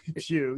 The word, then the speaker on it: Pew: The PU Research Center. – Pew. Yes. – Is that how it's pronounced? – Pew.